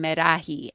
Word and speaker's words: Meraji: you pronounce my last name Meraji, and, you know, I'm basically Mexican.